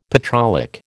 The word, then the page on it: petrolic: American English